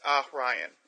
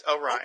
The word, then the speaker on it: Orion: According to the pronunciation, it's Orion. A-H.